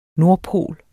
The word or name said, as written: nordpol